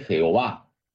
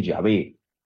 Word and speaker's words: Yahvé: No se dice Jehová, se dice Yahvé. Las cuatro letras sagradas, Y, H, W, H se pronuncia Yahvé,